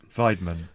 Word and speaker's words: Weidmann: I know you're talking about the prospect of Euro QE, but, you know, Mr. Weidmann is being extremely vocal in his opposition of it. – Weidmann. – I will bow to your superior knowledge of German pronunciation there.